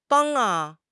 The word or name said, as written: bang1